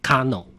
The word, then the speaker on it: KANO: 今天呢，我们要来介绍算是一个热血棒球的电影《 Kano》。 – 是这个《 Kano》呢，主要的这个故事的这个发生的这个背景呢，是在日本统治台湾时期的 1931年啊，当时呢。在这个嘉义农林啊棒球队啊，它其实就是嘉农了啊，用日文啊来发音就叫做 Kano 啊。